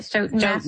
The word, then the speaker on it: Stoughton: Stoughton, Massachusetts.